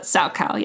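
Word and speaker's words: Salchow: It's spelled Saukal. That's why I said chow, but Saukal, yes.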